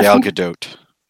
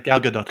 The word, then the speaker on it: Gadot: I didn't want to say anything before, but I did think that you pronounced the T at the end. – Gal Gadot. – No, hold on. Hang on. I'm listening to her telling me how to say it. – Wait, you know her? Invite her to the island. Come on. – It's Rebecca. Oh, it's very subtle. When she says it, she's saying the T, but it's almost inaudible.